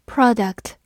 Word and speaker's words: product: product p r o d u c t product p r o 发 pro d u c t duct product product 美式发音当中字母 o 发的嘴型比较大，读作 product。